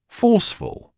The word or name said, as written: forceful